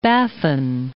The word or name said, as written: Baffin Bay